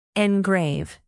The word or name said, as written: engrave